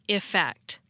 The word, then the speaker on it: effect: The word with an E is pronounced effect, almost like it has an I sound at the beginning.